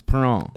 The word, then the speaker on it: Perron: Is there the French flair to it? – Because if there is, then it's Perron.